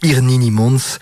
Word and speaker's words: Irnini Mons: Autre groupe prometteur dans un registre rock, Irinini, pardon, j'ai mal prononcé, Irnini Mons, jouait le jeudi dans le hall 3 du Parc Expo.